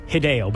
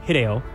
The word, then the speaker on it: Hideo: Typically in Japanese, you put the stress on the first syllable, where us in the West, we tend to put it on the second or last, so it would look like Hideo, but it's probably Hideo.